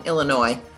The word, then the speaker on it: Illinois: Yeah, and anybody who says Illinois, oh my God, I just want to smack them upside the head. The S is silent, folks. Silent S on Illinois. Whatever.